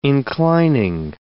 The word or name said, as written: inclining